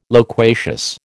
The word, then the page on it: loquacious: American English